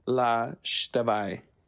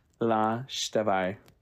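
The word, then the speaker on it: La Xtabay: it's the story of La Shtabai. That's how it goes. Trust me, I've looked into this as much as I possibly can, and the only way that it's been pronounced is La Shtabai.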